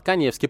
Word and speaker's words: Каневский: У меня Инстаграм с галочкой, фамилия моя пишется через А. Эдуард Каневский.